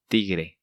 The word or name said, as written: Tigre